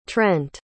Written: trent

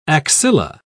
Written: axilla

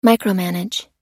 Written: micromanage

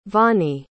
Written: vonnie

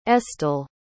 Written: estel

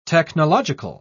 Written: technological

